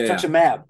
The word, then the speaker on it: rituximab: Okay, getting back into football shape and whatnot with the rituximab. Did I say that right? – Rituximab, yeah. Yeah. – Rituximab.